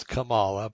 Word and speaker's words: Kamala: Biden has a snowball's chance of hell of getting elected, and Kamala Harris, and I know a lot of people say it's Kamala, but I like to mispronounce it on purpose.